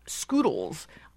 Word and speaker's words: Skoodlez: Okay, yes, Macy's getting into social computing, called Scoodles. But it's spelled strangely, S-K-O-O-D-L-E-Z. – Yeah, it's spelled Scoodle Z.